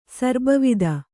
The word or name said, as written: ಸರ್ಬವಿದ